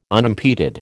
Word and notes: unimpeded: American English

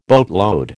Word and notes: boatload: American English

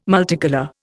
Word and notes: multicolour: Indian

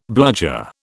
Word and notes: bludger: British English

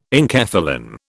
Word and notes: enkephalin: British English

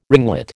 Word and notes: ringlet: American English

